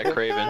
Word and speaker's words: Kraven: Kraven, is that how you pronounce it? Or, yeah, yeah, Kraven.